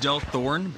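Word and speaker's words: Delthorne: It's pronounced Del Thorne, but yeah, it's kind of like a mix between Hawthorne and Del whatever Del is supposed to be.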